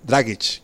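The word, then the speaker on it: Dragić: Dragic, que é o nome certo, eu sempre pronunciei errado, depois que eu aprendi isso.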